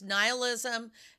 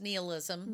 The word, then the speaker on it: nihilism: Nihilism, which can also be pronounced nihilism. – Yeah. – Which is how they pronounced it in the movie. And I was like, what? I always thought it was nihilism. It is actually pronounced both ways.